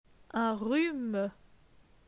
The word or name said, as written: Un rhume